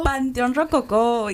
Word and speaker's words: Panteón Rococo: Panteón Rococo. – Panteón Rococo.